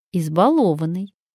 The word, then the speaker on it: избалованный: Какой же правильный ответ? «Избалованный». Какую закономерность мы замечаем в этих словах? Закономерность одна. Ударение не падает на первый слог. И даже в глаголе балую, ударение падает на второй слог, а не на первый.